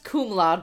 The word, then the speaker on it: cum laude: It's not even pronounced that way. It's pronounced cum laude, but fine.